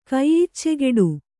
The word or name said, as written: ಕೈಯಿಚ್ಚೆಗೆಡು